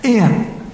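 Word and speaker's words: en: It's E-N. That's not a hard Greek word. Even I can pronounce that. In. Okay? In. It's the same word.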